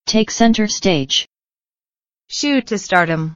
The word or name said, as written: take center stage